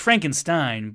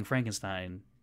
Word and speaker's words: Frankenstein: Frankenstein or Frankenstein? How do you say it? – Well, it's Frankenstein, but if you watch Young Frankenstein, it's Frankenstein.